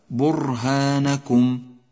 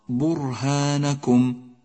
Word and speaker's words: برهانكم: وأخيرا لاحظ كلمة برهانكم، برهانكم حرف الراء ساكن، وإذا لم تتنبه إلى لفظه بشكل صحيح تكرر معك في النطق، أي هكذا برهانكم، والصواب برهانكم، أي اقرأ راء واحدة.